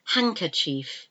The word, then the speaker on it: handkerchief: It's left unpronounced, it's not said, and it's like a D in handkerchief, handkerchief, handkerchief.